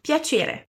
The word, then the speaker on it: piacere: Na palavra piacere, temos a letra C seguida de E. O que é mesmo que acontece nesse caso? Temos a pronúncia doce, assim como ti em ti vediamo.